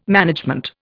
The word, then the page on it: management: Indian